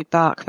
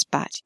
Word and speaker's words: Bach: And it is pronounced batch. People look at me strangely sometimes because they think it should be bark, but actually in this context, no, it isn't. It's batch.